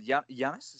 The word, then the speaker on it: Yannis: Another one is you're singing Yannis. Is that how you say his name? – Yeah, exactly.